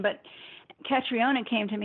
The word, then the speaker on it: Catriona: Catriona, is that how you say her name, Catriona? – Yes.